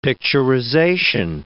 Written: picturization

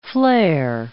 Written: flare

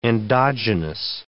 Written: endogenous

